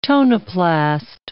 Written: tonoplast

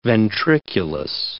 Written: ventriculus

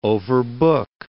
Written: overbook

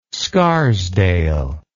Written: scarsdale

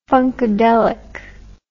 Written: funkadelic

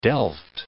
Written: delft

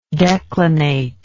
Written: declinate

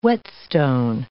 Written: whetstone

